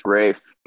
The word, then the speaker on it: Rafe: The the proper pronunciation is is it's Rafe, but uh, got it. Rafe, my mom my got it.